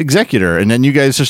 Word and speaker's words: Executor: He says, to help answer your confusion, Vader's destroyer is pronounced executor as in executive, a maker of policy, not the other way, executor as in killing someone. That word is executioner.